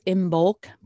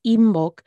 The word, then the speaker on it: Imbolc: Another fun little tie-in to the theme of balancing is that Imbolc, or you may hear it pronounced as Imbolc, but it's Imbolc, is in February.